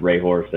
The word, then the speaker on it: Hrehor: So that's my last name pronounced Rahor, so N Rahor Photo, or NHREHOR.FOTO.